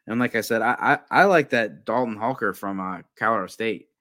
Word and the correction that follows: Holker: Yeah, yeah, a Dallin Holker, H-O-L-K-E-R. – H-O-L-K.